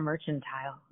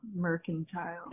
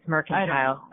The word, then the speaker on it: Mercantile: Is it Mercantile or Merchantile? – Mercantile. – It's Mercantile.